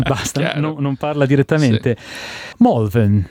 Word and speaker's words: Malvern: Secondo me è più comune la pronuncia Molven. Malvern. Però mi ricordo anni fa era abbastanza diffusa la pronuncia Malvern.